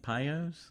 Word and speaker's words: Pio's: Pio's? – Yeah. – Is that how you say it? – P-I-O bagels.